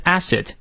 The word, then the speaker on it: acid: 后面的 acid 也是梅花 a 啊。